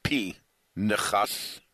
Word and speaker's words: Pinchas: And even though I call it Pinchas, the real pronunciation of the name is P-Nechas, although only pure Israelis will say that on a person's name.